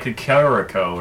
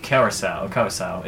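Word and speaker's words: Curaçao: There's this liqueur called Curacao, and I always called like Cacarico or something like that. And what? – It's pronounced Curacao.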